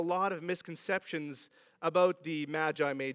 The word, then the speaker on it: Magi: It's apparently not Magi, it's Magi, which sounds really weird to me. But I checked, I did the pronunciation button.